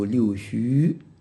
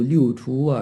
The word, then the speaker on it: 六畜: 我们现在读六出啊，是从多数人这么读，少数人呢，按照古时候通用的读音呢，也有读六虚的，这个我们也要了解。